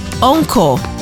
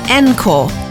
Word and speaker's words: encore: Encore is pronounced encore, not encore.